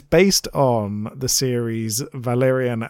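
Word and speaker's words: Valérian: The reason for the name Valerian, because it's French. That's the proper French pronunciation.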